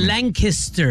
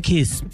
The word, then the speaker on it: Lancaster: Caribe, Lancaster. – ¿Por qué no le ponen Kiss? Y pone, porque se deletrea Caster con C-A-S-T-E-R, Caster.